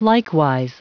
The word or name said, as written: likewise